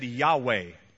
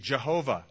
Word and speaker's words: Yahweh: Here, if you were reading the Hebrew, it's a tetragrammaton, a four lettered, four consonant word that is typically said Yahweh or the old pronunciation, Jehovah.